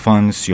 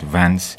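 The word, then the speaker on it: Vance: سایروس فانس یا آنطور که گاهی تلفظ می شود ونس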